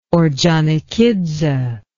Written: orjonikidze

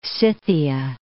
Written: Scythia

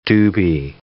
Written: doobie

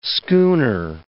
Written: schooner

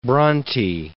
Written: Bronte

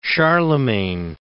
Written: Charlemagne

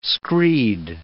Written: screed

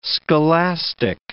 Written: scholastic

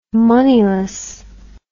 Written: moneyless

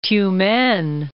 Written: Tyumen'

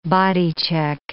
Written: bodycheck

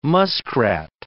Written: muskrat

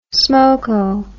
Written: smokeo

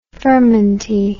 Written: furmenty

